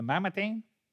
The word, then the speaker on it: Mamertine: This is written probably from the Marmatime? I don't know if I'm saying that right. The Mamertine, right?